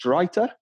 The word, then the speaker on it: Drita: So yes, Linfield had been drawn in the first round of qualifying for next year's Champions League against Drita. Is that how you do it? I'm pretty certain that's how you say it, yeah.